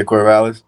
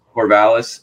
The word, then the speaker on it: Corvallis: But, you know, so Corvallis, right? Am I saying it right? – Is it? Yeah, Corvallis. – Corvallis.